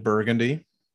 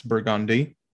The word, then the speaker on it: Burgundy: Cause there's many of videos on, like we have a street named Burgundy, but that is not how it's pronounced. It's Burgundy. Shit like that.